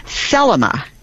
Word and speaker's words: Thelema: And what she was asking about is something called Thelema. T-H-E-L-E-M-A with the emphasis on the first syllable.